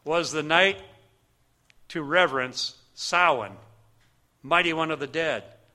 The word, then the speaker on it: Samhain: Now, the most important religious day for the Druids was the celebration of, we would pronounce it Samhain, but it's actually Samhain, Lord of the Dead, basically Satanism.